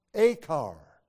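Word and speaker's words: akar (עכר): The word trouble is from a Hebrew verb that is pronounced akar, meaning to trouble, and it's from that verb that the name Achan is derived.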